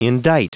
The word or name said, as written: indict